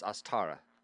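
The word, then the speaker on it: Ostara: Astara, you don't want the word star to come out. It's Astara. Astara. – Like us, but with an O. Us. – Us. – Astara.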